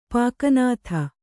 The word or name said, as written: ಪಾಕನಾಥ